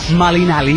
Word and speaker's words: Malinalli: Malinalli, que es hierba, se pronuncia Malinalli.